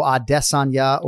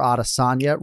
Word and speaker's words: Adesanya: And I vowed to not have a pronunciation conversation today because, yes, you can go Adesanya or Adesanya, right? But the one thing you cannot do is have the H sound.